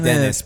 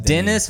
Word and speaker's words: Denis: It's spelled like Dennis, but he pronounced it Denis.